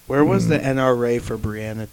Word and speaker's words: Breonna: It's spelled different, but it's Breonna. – It's like Bree. It's B-R-E-O. It's like, and then Anna.